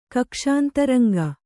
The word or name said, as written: ಕಕ್ಷಾಂತರಂಗ